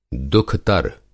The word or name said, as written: दुख़तर